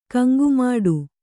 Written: ಕಂಗುಮಾಡು

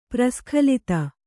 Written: ಪರಿಸ್ಖಲಿತ

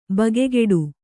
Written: ಬಗೆಗಿಡು

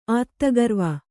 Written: ಆತ್ತಗರ್ವ

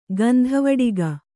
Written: ಗಂಧವಡಿಗ

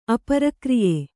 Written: ಅಪರಕ್ರಿಯೆ

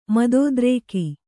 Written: ಮದೋದ್ರೇಕ

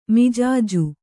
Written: ಮಿಜಾಜು

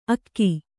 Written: ಅಗೊ

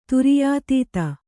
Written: ತುರ್ಯಾತೀತ

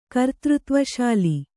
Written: ಕರ್ತೃತ್ವಶಾಲಿ

